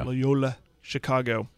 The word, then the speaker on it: Loyola Chicago: But give him time to get his guys that runs his system like he had at Loyola Chicago. Am I saying that right? – Yeah. – Loyola Chicago.